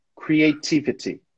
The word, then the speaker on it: creativity: Essa aqui a gente pronuncia creativity. Creativity. Com sotaque americano, que é o seu caso, creativity. Creativity. Em inglês britânico, creativity, tá?